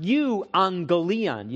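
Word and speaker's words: euangelion (εὐαγγέλιον): In Greek, it's pronounced euangelion.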